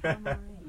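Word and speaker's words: Damare (黙れ): To say shut up in Japanese. – Future career. – Which is pronounced Damare.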